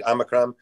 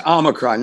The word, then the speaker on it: Omicron: Omicron. And please. Omicron. Make- yes. – Omicron, not Omnicron, but it's okay.